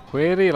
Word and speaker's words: Query: vedete voi come volete pronunciarla, è la rivista del CICAP, eh, Query, la scienza indaghi i misteri, questa è la è la pronuncia forse corretta, voluta quando l'abbiamo eh, inventata.